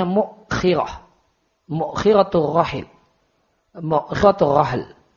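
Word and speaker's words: mukhirah (مؤخرة): Nawawi mengatakan mukhirah cara bacanya mimnya di dhamma. Ya, wa kasil kha, kha-nya di kasrah. Mukhirah. Wa hamzah dan ada hamzah yang dibaca sukun. Dan ada yang mengatakan kha-nya dibaca fathah, mukhirah.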